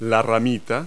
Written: twig

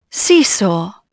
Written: sees

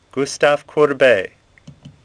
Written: Courbet

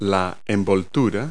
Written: housing

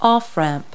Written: off ramp

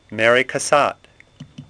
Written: Cassatt

